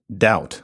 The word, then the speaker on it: doubt: What about a case like daubit, doubt, with the B in it, which was never pronounced in our language? It was basically jammed in there by some underoccupied person who thought that we needed to be reminded of dubitare and Latin roots like that.